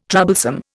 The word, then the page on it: troublesome: Indian